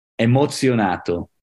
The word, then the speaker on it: emozionato: Emozionato.